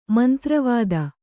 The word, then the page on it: ಮಂತ್ರವಿದ: mantravida